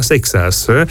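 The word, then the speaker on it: Seixas: Pogacar, sì, a 20 anni, 16 giorni, Sexas, è il più giovane a vincere, è così la pronuncia esatta, Sexas,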